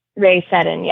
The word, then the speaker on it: Rhea Seddon: Those names Sally Ride, Judy Resnik, Anna Fisher, Kathy Sullivan, Shannon Lucid, and Ray Sh- Rhea Seddon. Is that how you pronounce that? – Rhea Seddon, yeah.